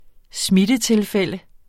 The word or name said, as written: smittetilfælde